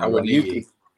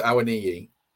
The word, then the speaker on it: Awoniyi: If he was going to play two up front, you'd play Dennis and Awoniyi. How do you pronounce that again? I keep getting Awoniyi. Well, I was told it was Awoniyi.